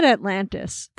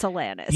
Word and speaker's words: Alanis: Alanis. I think you said Atlantis. – Probably. – I'm not sure, but we all know it's Alanis.